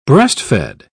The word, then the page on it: breast-fed: British English